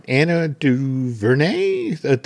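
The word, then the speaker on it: Ava DuVernay: All right, Anna DuVernay? – Yeah, you did it.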